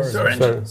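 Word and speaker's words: Swearengen: Swearingen.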